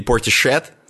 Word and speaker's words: Portishead: Portishead вот эти, или Portishead, наверное, правильно все-таки читается,